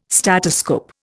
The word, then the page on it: statoscope: Indian